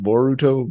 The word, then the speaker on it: Boruto: You gonna pronounce it right, or is it Boruto? Baruto? – It's Boruto. – Boruto, okay, like burrito, but the other way.